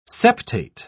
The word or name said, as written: septate